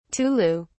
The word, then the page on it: tulu: British English